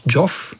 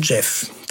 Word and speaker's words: Geoff: Und übrigens, seinen Vornamen schreibt man ja G-E-O-F-F, aber man spricht nicht Joff, wie viele Leute das tun, sondern Jeff.